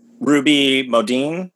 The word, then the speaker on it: Ruby Modine: You know, I mean, and I love like Ruby Modine. Is that how you say it? Yeah, yeah.